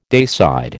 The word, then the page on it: dayside: American English